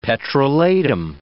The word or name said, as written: petrolatum